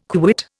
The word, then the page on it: quit: Indian